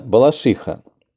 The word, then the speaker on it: Балашиха: Это я с детства, там все местные говорили Балашиха. Правильно, Балашиха, но это уже такой местный сленг.